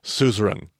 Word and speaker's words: suzerain: Because in the ancient world, we had what was called, I used to say Caesarian. But I found out today, in fact, that I've been pronouncing that wrong this entire time. It's actually suzerain, but we call them suzerain vassal covenants. And in the ancient world, there was two parties to a covenant, a suzerain and a vassal. – And just to clarify, that's spelled S-U-Z-E-R-A-I-N.